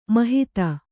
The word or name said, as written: ಮಾಹತ್ತು